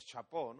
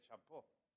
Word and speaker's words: chapeau: Bien, Guillermo Tell, o héroe de la independencia suiza, tiene una historia que es o chapó, ¿no? Sombrero, ¿cómo se dice sombrero? Chapeau. No, chapeau, en francés, chapó. También se utiliza en francés para camisinha, pero en ese caso se pronuncia chapó.